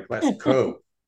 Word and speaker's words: Glasgow: Glasgow, okay, I'm sorry, Glasgow. – Yeah, Glasgow. You have to say it right, otherwise there'll be trouble. They get angry easy, these guys from Glasgow. Just saying. – Okay. It's not a cow, it's a coat.